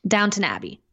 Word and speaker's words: Downton: No, there's no W. Mm. Well, there's one. – Well, British people don't pronounce the W, so it's Downtown Abbey. Okay, whatever. Never seen it. – No, I'm fucking with you. It's Downton Abbey.